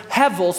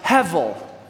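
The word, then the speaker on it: hevel (הֶבֶל): It's the word hevel, spelled hebel, but pronounced hevel.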